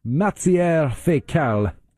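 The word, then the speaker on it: Matières Fécales: Yeah, I said it right. Matière fécale en français.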